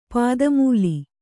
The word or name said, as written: ಪಾದಮೂಲ